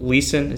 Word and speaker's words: Leeson: You're the founder and co-owner of Leeson.